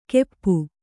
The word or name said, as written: ಕೆಪ್ಪು